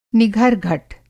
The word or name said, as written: निघर-घट